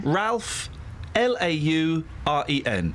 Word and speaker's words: Lauren: Ralph, Lauren.